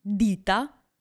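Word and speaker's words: dita: Хорошо, итальянское слово «дита» означает «пальцы», а слово «дитта» в этом случае, как вы понимаете, в слове две буквы «т» означает «фирма». – «Дита», «дитта».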